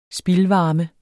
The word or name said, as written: spildvarme